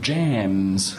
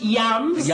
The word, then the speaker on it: Jams: Yes, it was a Dutch supergroup called Jams. Yes. They weren't quite as popular as ABBA. – It's pronounced Yums.